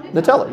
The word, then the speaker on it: Nutella: No, Nutella.